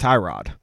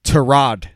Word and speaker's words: Tyrod: His name is pronounced Tyrod Taylor.